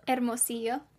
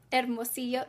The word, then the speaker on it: Hermosillo: Hermosillo. Just don't say the H.